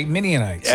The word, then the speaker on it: Midianites: Okay, Midianites. – And it rhymes. It's good that you can remember it rhyme-wise. Gideon, Midian.